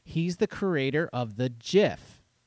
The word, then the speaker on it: GIF: It is a soft G pronounced GIF. End of story.